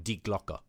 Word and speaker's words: Die Glocke: So it's actually two words, D-I-E, as like die, as we would pronounce it, and Glocke, not Glock like the gun, but with an E at the end of it.